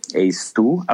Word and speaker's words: ACE2: Und diese Rezeptoren nennt man ACE, also ACE2, ACE2 auf Englisch ausgesprochen, und die hat man nun mal vor allem in, in Organen wie Lunge, Darm, Niere und Blutgefäßen.